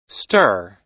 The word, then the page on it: stir: American English